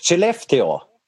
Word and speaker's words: Skellefteå: Skellefteå.